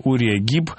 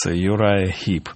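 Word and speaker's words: Uriah Heep: Это такой гадкий персонаж по имени Урия Гиб. Так, по крайней мере, оно звучит в русскоязычной версии в Дэвиде Копперфилде. Ну, а по-английски оно произносится как раз Юрай Гиб.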